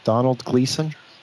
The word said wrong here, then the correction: Domhnall Gleeson: Domhnall Gleeson? – What was the character's name of the auto?